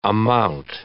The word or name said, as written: amount